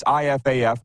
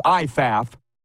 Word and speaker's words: IFAF: That's IFAF. They want it pronounced IFAF.